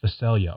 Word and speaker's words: Phacelia: In other words, I was completely mispronouncing it. It's Phacelia.